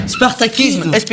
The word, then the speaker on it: Spartakisme: Spartakisme.